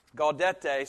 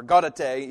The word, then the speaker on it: Gaudete: Uh, the first thing is to say this this Sunday is Gaudete Sunday, or Gaudete, either way you want to pronounce it in Latin.